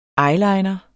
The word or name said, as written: eyeliner